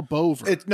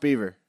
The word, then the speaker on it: Boever: I think it's Joe Bover. – No. It's pronounced Beaver. – Okay, Joe Beaver's. – Come on. – B-O-E-V-E-R. Yeah, it's pronounced Beaver. For sure.